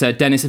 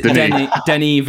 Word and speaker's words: Denis: So that's Denis Villeneuve in 2015, and then Dari Zuwulski in 2018. Yeah, nailed it. – I believe the S is silent in the first name. – Oh, really? Is Denis Villeneuve.